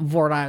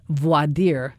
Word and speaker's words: voir dire: And by the way, when you select a jury, here they say it's voir dire, because it's not voir dire, it's voir dire. It's a French, you know, when you select a jury, but it's spelled V-O-R, then D-I-R-E. Well, in French, it's voir dire.